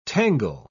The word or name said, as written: tangle